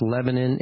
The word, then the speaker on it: Lebanon: It's pronounced Lebanon. It's spelled Lebanon.